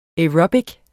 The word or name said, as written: aerobic